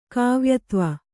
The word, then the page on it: ಕಾವ್ಯತ್ವ: Kannada